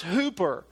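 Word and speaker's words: hooper (ὑπέρ): And in Greek, it's pronounced hooper, but in English, it's pronounced hyper.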